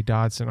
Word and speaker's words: Dodson: Is it Dobson or Dodson? – Dodson. – With a D, Dodson. All right. – D as in dog.